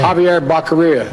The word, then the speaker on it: Javier Becerra: Yes, there's a different way to pronounce that name. – Javier Becerra. – Mm-hmm. Correct. Yes.